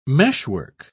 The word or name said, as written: meshwork